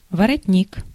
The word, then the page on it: воротник: /vərɐˈtʲnʲik/; vorotnik